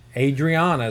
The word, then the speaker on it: Adriana: Well, it's Adriana. Adriana is how I pronounce that. – And what's the last name? – Milo. M-E-L-O.